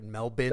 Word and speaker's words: Melbourne: That's how you say it, right? Melbourne. – Melbourne. – Just forget all of the R's.